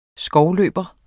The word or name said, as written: skovløber